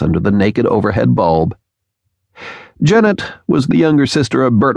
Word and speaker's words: Janet: Oh, hi, Janet. She betrayed herself as a foreigner by pronouncing the name to rhyme with "Gannett." Janet Wadman herself, like a good New Brunswicker, used the gentler Janet.